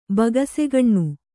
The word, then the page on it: ಬಗಸೆಗಣ್ಣು: bagasegaṇṇu